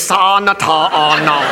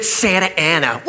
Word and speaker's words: Santa Ana: How? It's Santa Ana. What says Santa Ana? Look, I know I didn't finish school, but that frickin' says Sa Nata Na. They pronounce it Santa Ana.